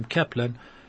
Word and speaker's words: Kaplan: Um, in English, a very simple name like Kaplan, my name Kaplan, could be spelt with a K as K-A-P-L-A-N.